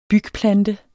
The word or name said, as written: bygplante